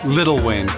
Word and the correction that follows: Lil Wayne: Lil Wayne.